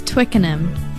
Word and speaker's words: Twickenham: Pronounce it now. – It's Twickenham.